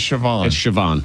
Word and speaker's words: Siobhan: S-I-O-B-H-A-N. – Siobhan. – With a B? – Raymond Noodles. – It's Siobhan.